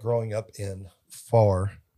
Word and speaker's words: Pharr: And a lot of people don't know where Far Texas is. And Far Texas is spelled P-H-A-R-R? – Yes.